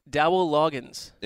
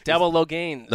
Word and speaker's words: Dowell Loggins: How do you pronounce the offensive coordinator of Chicago's name? – Dowell Loggins. – Incorrect. – Dowell Loggins. – No, Dowell Loggins. – Okay. Well, I stand corrected.